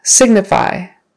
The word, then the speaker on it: signify: Signify. Signify. First syllable stress.